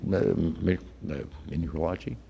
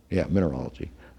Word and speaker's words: mineralogy: mineralogy? No, I'm not pronouncing that right. Mineralogy.